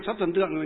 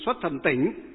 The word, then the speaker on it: xuất thần tỉnh: Nhưng mà xong cái này thì xuất thần tượng thì đọc là thay vào xuất thần tượng thì xuất thần tỉnh. Chứ tỉnh có nghĩa là cái giếng.